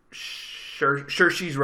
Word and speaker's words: Saoirse: Saoirse Ronan, that's how you say her name.